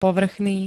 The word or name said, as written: povrchný